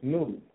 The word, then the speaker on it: Nod: they settled in the land that was called Nod, pronounced Nud, which means wanderer.